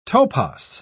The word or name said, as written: topos